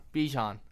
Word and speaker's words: Bijan: Bijan, I feel like, am I saying that right? – B Bijan. – Okay. Never heard. Okay. Bijan.